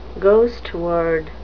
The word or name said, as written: ghost word